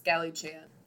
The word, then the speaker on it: Galichan: I don't care what you say, his name is pronounced Galichan. Like the rejected anime character that he is.